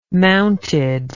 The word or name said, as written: mounted